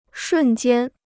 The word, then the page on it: 瞬间: shùn jiān